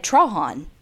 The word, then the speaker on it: Trahan: Well, she's from Lafayette. And they were like, okay, there it's actually pronounced Trahan.